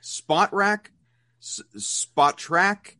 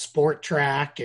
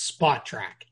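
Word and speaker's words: Spotrac: A lot of people mix some letters in that aren't in there. They call it Sport Track and Sport Rack and all these other things. It's Spot Track.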